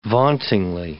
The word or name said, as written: vauntingly